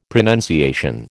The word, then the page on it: pronunciation: American English